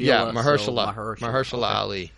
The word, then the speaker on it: Mahershala Ali: I'm going to let you say his name. – Mahershala Ali. – Mahershala? Yeah. – Yes. – Is that right? – Mahershala Ali. – Mahershala. – Yeah, Mahershala Ali.